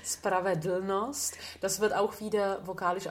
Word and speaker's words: Spravedlnost: Lucia said that this L will turn into a very neutral vowel sound. And now she goes on to ask me how I would describe it. – Ja, Spravedlnost.